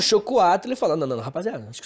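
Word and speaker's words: xocolatl: Na verdade, a pronúncia, eu posso estar pronunciando errado, mas é com X. É chocoatle.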